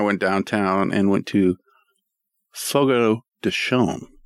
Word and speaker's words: Fogo de Chão: Not Fogo de Chão. – Chão? – Yeah. That's what the server told me. It's got like a Brazilian little squiggle over one of the letters and it makes it sound different.